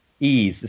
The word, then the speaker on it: Ys: Ease. It's spelled Y-S.